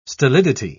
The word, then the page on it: stolidity: British English